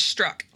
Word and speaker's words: Strzok: It is Strzok, like the word strike, the passive strike.